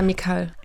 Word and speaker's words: Mikal: Naja, also Mikal auf der zweiten Silbe betont. – Ja, Mikal.